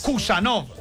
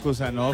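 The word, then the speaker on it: Khusanov: ¿Cómo es? – Kusanov. – Ah, ok, Kusanov.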